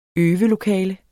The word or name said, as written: øve